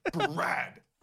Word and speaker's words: Brad: It's Brattle. – Yeah, right, we forgot to deal with the L. – The underline, it's the underline. It's Brad. Underlined. – Brad.